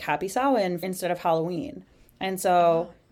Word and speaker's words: Samhain: It's spelled Samhin, but it is pronounced Samhain.